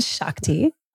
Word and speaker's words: Shakti: S-H-A-T-K-I. – S-H-A-K-T-I. – Oh, dang it. – That's okay. Aspen Shakti. – Shakti. – Yeah. Oh no, everybody. That's how people pronounce it.